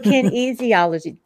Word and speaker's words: kinesiology: Kin easy ology oh, kinesiology.